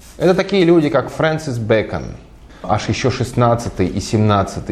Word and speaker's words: Бэкон: Фрэнсис Бэкон, Бэкон, ну как бекон почти, да, только через «э», по-английски так и пишется, и Томас Хоббс.